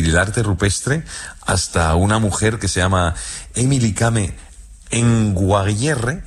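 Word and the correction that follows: Emily Kame Kngwarreye: y lo he dicho mal. – Emily Kame Conguarreye.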